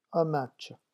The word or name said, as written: un match